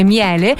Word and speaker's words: Miele: Senti qua, solo per tutto il mese di ottobre super promozioni sugli elettrodomestici Miele, che si scrive miele ma si legge Miele, quindi attenzione.